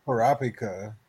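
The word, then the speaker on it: Kurapika: Kapika, how you say it? – Harapika.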